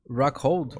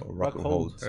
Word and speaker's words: Rockhold: Rockhold? – Rockhold.